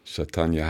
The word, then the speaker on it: Chaitanya: Und dann kommen wir zu, jetzt korrigiere mich, wenn ich das falsch ausspreche, Swami Chaitani Hari. Chaitanya Hari heißt er, Chaitanya Hari.